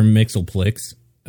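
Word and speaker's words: Mxyzptlk: Superman is super susceptible to magic stuff, like Mr. Mixelplix. Further. – Wow, wait, good job on the pronunciation. I was curious if you'd be able to get that, and that was perfect.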